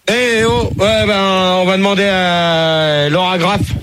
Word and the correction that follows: Lara Croft: Lara Croft. – Bon, la Croft. Ouais, ben, je sais pas qui c'est qui m'a écrit ce nom-là, mais je prononce comme c'est marqué.